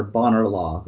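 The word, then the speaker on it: Bonar Law: Differently spelled, but pronounced the same way as the short-serving UK Prime Minister Bonner Law, whose name is much more fun to say as Boner Law.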